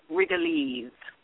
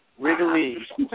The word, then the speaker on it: Rigolets: And so when I look at a chart, I look at it and it looks to me like Rigolets. But if you want to sound like a local, it's called Wrigley's. – Wrigley's.